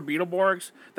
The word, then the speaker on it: Beetleborgs: You said that right? Yeah, Beetleborgs.